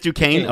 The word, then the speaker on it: Duquesne: Then we see the arrest of Jack Duquesne. Is that how you pronounce that? Duquesne? – Duquesne? – It's just Duquesne? Okay. Jack Duquesne, is the SNE, is that like a silent thing?